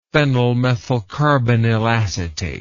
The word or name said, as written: phenylmethylcarbinyl acetate